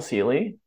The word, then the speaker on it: Ceely: Yeah, so my name is Michael Seeley. My last name is spelled C-E-E-L-Y, pretty unique spelling.